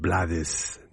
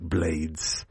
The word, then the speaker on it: Blades: I had someone text in last week appreciating the pronunciation of Blades, not Blades. He goes by both, but it's Blades. Well, in my book.